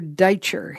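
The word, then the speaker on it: Dajczer: I was reading an excerpt by Father Deitcher.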